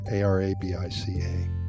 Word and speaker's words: Arabica: It is actually illegal in Costa Rica to produce anything other than 100% Arabica coffee beans. And I think I'm pronouncing that wrong. Arabica.